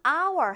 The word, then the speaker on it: hour: an hour 한번 보세요. h로 시작하고 있지만 h가 발음되지 않아서 발음은 아, 모음 발음으로 시작하죠.